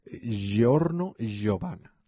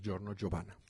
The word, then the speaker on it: Giorno Giovanna: este, y en la parte 5 es Giorno Giovanna. Nada más que Jorno Giovanna se escribe con G, pero sí es Giorno Giovanna.